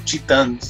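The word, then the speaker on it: Titãs: Chitans, se escribe T-I-T-A-S, Titas, pero se, habla Chitans. La primera vez que escucho Chitans, dije, ¿qué es esto?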